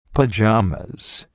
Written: pajamas

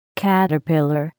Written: caterpillar

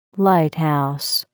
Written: lighthouse

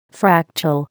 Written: fractal

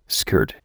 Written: skirt

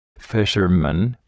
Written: fisherman

